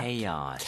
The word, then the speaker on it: chaos: 발음 주의하셔야 돼요. 영어로는 chaos. Y가 중간에 있는 것처럼 연결해서 chaos. – Chaos. – 카오스 안 되죠? – 안 됩니다.